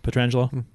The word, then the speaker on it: Pietrangelo: Notice how I pronounce his name correctly, Pietrangelo.